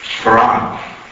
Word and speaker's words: Fard: the mysterious teacher Farad, spelled F-A-R-D, but pronounced Farad.